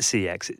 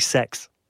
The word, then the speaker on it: CeX: Actually, you don't pronounce it CeX. It's actually sex.